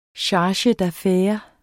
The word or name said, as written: charge